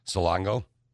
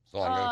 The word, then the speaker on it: Salonga: How do you say that? Salonga. Salongo? – Salonga. I'm not really. – Salonga. Like Salonga. Baloney. Or Saloni.